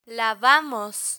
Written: lavamos

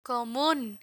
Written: comun

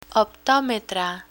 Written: optometra

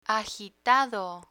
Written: agitado

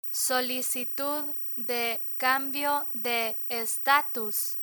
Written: solicitud de cambio de estatus

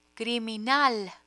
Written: criminal

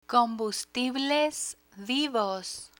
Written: combustibles vivos

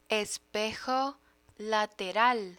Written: espejo lateral

